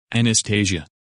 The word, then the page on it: Anastasia: English